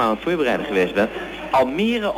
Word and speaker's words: Almere: Maar in elk geval, zoals het geschreven staat, moet je het uitspreken als Almere. – En om alle misverstanden dan maar kort te sluiten, we zitten ook niet in Almere stad.